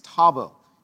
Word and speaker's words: Tabel: And it's actually, the Hebrew name for this is Tabel, T-A-B-E-L. It's actually the better pronounced Tabel.